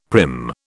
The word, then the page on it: prim: British English